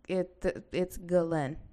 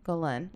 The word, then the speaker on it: Ghislaine: Okay, so that lady, Ghislaine Maxwell. – Ghislaine. – How do you say her name? – So it's Ghislaine. – Ghislaine? – Ghislaine, like Glenn, which is weird.